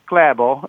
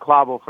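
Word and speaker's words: Klæbo: Yeah, so Johannes Hoslot Klabo, it's actually pronounced Klabo, but it's, you know, we've settled on Klabo for purposes of broadcasting.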